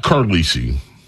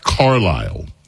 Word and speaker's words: Carlisle: It's not Carlisi. The Countess of Conspiracy sent me a note. Excuse me. Her name is pronounced Carlisle.